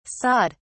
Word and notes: sawed: British English

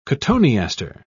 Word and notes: cotoneaster: British English

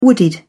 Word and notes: wooded: British English

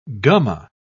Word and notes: gumma: British English

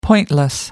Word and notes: pointless: American English

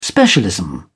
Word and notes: specialism: British English

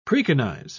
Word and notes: preconize: British English

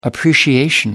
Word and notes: appreciation: American English

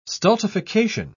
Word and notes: stultification: British English

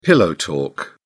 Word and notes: pillow talk: British English

